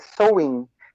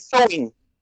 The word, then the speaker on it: Samhain: e você pode ver a pronúncia correta, é Sowin. Se escreve S-A-M-H-A-I-N. É, e a é, é, pronúncia celta é Sowin.